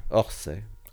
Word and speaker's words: Orsay: Orsay. O-R-S-A-Y.